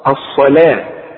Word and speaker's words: الصلاة: الصلاة مكتوبة بالواو لكن تنطق بالالف، شوف الصا، الصلاة، واخد بالك.